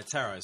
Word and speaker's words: tarot: They're the tarot size cards. I believe. – They are tarot size cards. Doesn't rhyme with carrot. And, No, tarot, sorry. Yeah. Rhymes with barrow.